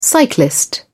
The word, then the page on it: cyclist: British English